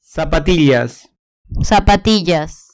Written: zapatillas

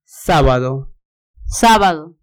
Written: sabado

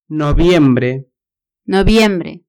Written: noviembre

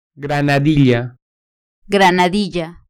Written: granadilla

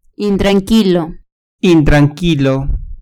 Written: intranquilo

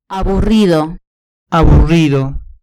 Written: aburrido

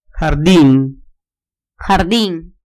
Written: jardin

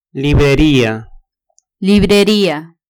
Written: libreria

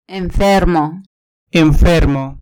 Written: enfermo

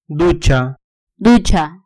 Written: ducha

